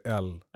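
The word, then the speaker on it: Elle: Man skriver ju Ellegalan, men man uttalar det L. – L. – Det är hon på franska.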